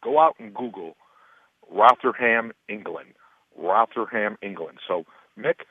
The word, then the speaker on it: Rotherham: It's pronounced Rotherham. It's in Yorkshire, in the north of England, and you're absolutely right.